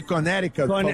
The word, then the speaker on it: Connecticut: Pode repetir essa pronúncia de Connecticut? – Connecticut.